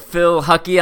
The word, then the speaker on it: Phil Hucke: Anyway, speaking of ninja training and working on OCR-specific skills, we have Phil Hucke on the podcast.